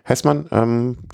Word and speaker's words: Hessmann: Das Heesmann. – Hessmann spricht man es aus, okay. Ich hätte es aufgrund, ja. Hessmann, Michael Hessmann, positiv getestet wurde.